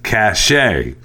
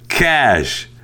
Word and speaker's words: cache: I found it ironic that you spent a lot of time criticizing the pronunciation and spelling in the story while continually mispronouncing the word C-A-C-H-E as cachet. That is how you pronounce the word C-A-C-H-E-T. C-A-C-H-E is pronounced cash.